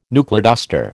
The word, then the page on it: knuckleduster: American English